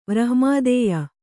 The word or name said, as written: ವ್ರಹ್ಮಾದೇಯ